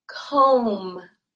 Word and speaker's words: comb: And we say comb. Comb. This B is silent.